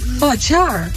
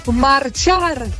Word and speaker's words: Marchar: Sorry dat ik het zeg, maar jullie moeten echt wat doen aan de uitspraak van jullie nummer Magyar. Ik denk, Magyar, wat bedoelen ze nou? Ik snap hem niet. Maar het is Marchar. – Oh. – De R moet beter worden uitgesproken, jongens. Marchar.